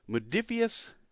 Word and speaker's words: Modiphius: With me, I've got on the line Chris Birch, publisher with Modiphius Entertainment. Is that right? – That's right. Yeah, that's it. – All right. It's a cool looking word that you're never sure how to pronounce it, but I like the way the word looked. – We made it up, so don't worry. It's a lot of people get it wrong, and you got it right, so it's all good.